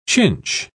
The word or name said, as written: chinch